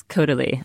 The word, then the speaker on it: Caudalie: So first, I would say is Caudalie or Caudalie? How do we say it? – I think it's Caudalie.